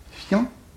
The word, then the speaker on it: fiancé: Prononciation et lecture, conversation et lecture, c'est fiancé, c'est-à-dire deux syllabes. Donc, il ne faut pas prononcer un i, mais on prononce un iode, fian, une seule syllabe, voyons.